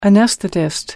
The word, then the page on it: anesthetist: American English